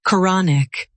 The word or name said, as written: quranic